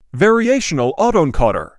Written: variational autoencoder

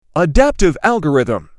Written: adaptive algorithm

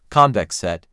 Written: Convex set

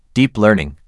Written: Deep Learning

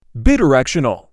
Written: Bidirectional

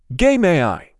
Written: Game AI

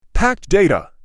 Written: packed data

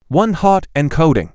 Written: One-Hot Encoding

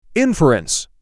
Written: Inference

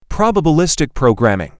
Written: Probabilistic programming

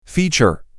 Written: feature

